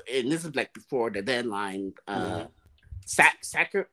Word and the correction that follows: Siakam: I can't even say his name. Toronto Raptors trade Sacker and Sacker. Ugh. Wow. Oh, Oh, Siakam. – So, Pascal yeah. Siakam.